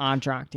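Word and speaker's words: Entr'acte: Entract. – Is that what it's called? Entract? – Entract, yeah.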